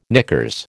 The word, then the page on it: knickers: American English